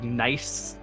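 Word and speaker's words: gneiss: And there's also some, how you pronounce it, like, nice, G-N-E-S-S. – You got it.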